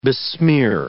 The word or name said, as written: besmear